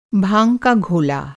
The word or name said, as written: घोला